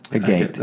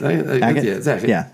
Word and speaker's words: agate: Did I say it right? Agate. – Agate. – Yeah.